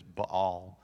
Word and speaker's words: Baal: I've always called him Baal, but the proper pronunciation is Baal.